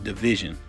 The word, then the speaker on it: DVSN: They're called Division, D-V-S-N, but it's pronounced Division.